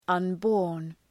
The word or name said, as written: unborn